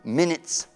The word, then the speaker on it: minutes: It's 22 minutes past one. It's four minutes. Vedi che l'U in minutes è, è, è pronunciato I, non è minutes, è minutes.